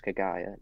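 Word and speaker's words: Kaguya: Because it's actually pronounced Kagaya, not however you're saying it.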